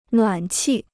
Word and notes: 暖气: nuǎn qì

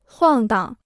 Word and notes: 晃荡: huàng dang

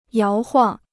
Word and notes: 摇晃: yáo huàng

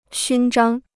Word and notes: 勋章: xūn zhāng

